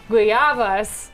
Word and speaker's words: guayaba: Today on 5 Live, we have good news for Robert's guayabas and avocados. Avocados. – Guayaba, you don't pronounce the G.